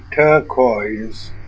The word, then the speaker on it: turquoise: Say turquoise using the vowels as in birth and noise, with a stress on the first syllable. Stress pattern Dadi.